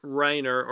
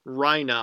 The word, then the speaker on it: Reiner: So they do have, so they've managed to capture both Bertholdt and Reiner, or Reina, I think is how, well, so how everyone says his name. Reina. So I guess that's the pronunciation.